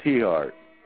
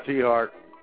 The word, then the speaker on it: Tiahrt: Todd T. Hart. Just Google the idiot factor, Todd T. Hart. By the way, his name is spelled T-I-A-H-R-T.